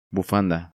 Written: Bufanda

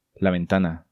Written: La Ventana

